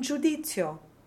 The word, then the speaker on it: giudizio: Then we have the Z's in astuzia, arguzia, and giudizio. Simone said that when a Z is followed by an I plus another vowel, the Z will be doubled, so phonetically T-T-S.